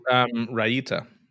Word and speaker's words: raita: Raita. – You making that pronunciation up? – No, raita. Just okay. Google it. We'll get our people to Google it for us.